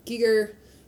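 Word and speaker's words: Giger: Geiger. That's how you say it, right? – Geiger, his influence, like those chains just swinging around.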